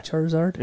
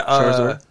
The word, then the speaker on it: Charizard: Charizard? – Yeah, Charizard.